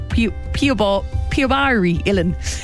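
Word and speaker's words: píobaí uilleann: One of the events you can do is you can go and try the pipes with Pobali Ilan, right? I pronounced that wrong. Piobari Ilan.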